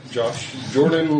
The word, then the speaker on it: Josh Jordan: Josh Jordan, like the country and the basketball star, but somehow pizza people still get it wrong.